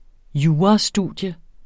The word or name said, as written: jurastudium